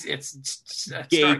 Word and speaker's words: Gaytin: Another quality series that, and if you want to check out my other podcast, Gayton, but it's spelled GAYTIN. – Okay, it's Gayton.